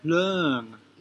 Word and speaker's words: Leung: And I also want help with correctly pronouncing your last name, Leung. Is that okay? – Leung. Yeah. – Like langue and parole. Leung.